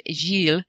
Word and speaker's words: Gilles: Sarah is busy romancing a Frenchman who, it's, help me out here, it's pronounced, or I'm sorry, it's spelled G-I-L-L-E-S. How is that pronounced? – Gilles. – Yeah. As in, you know, Jill.